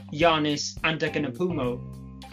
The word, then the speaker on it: Giannis Antetokounmpo: I mean, the two players I was talking about were Giannis Antetokounmpo and Nikola Jokic, of course. – Have you been practicing that pronunciation? That was fantastic.